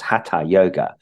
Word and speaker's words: Hatha: or what they call Hatha Yoga. It's pronounced Hatha Yoga.